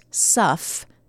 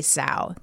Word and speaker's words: sough: Old Sal gets its name not from the sound that it makes, most likely from the word sow, S-O-U-G-H, okay? Pronounced suff. – Uh-huh. – But people got it wrong, right? They'd see the word and they'd say sow.